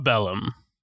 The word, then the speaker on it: Parabellum: Is that how you pronounce it? Parabellum? – Parabellum. Yep.